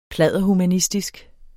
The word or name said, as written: pladderhumanistisk